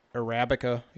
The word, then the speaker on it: Arabica: Arabica?